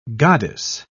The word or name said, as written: goddess